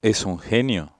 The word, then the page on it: genio: Spanish